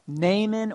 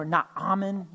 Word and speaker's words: Naaman: The widow of Zarephath, Naaman or Naaman, depending on how you want to pronounce that.